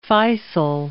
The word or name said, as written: Faisal